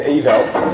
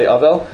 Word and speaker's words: Avel: The proper way to pronounce it really is Avel. Okay, but that's most people say Avel.